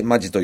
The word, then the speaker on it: 本気: ネクスコ東日本プレゼンツヤンの気ままにドライブ、今週はこの時期恒例の南魚沼で開催されております本気と書いてマジと読む南魚沼マジ丼を味わいにやってまいりました。